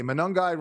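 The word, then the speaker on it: Monangai: Hey, Manungai rhymes with fun guy.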